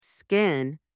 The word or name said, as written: skin